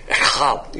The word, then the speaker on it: Echad: And if you get the Hebrew pronunciation, it's Echad. You got to kind of spray it out.